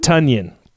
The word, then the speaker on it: Tonyan: Is it Tonyan? – Tonyan, I think, is how it is. Yeah, like Bunyans, but Tonyan.